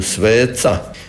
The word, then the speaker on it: sveca: Fonolozi kažu da to c u sveca izgovaramo malo napetije jer nam je u svijesti gubitak toga t, pa se to nekako nadomješta.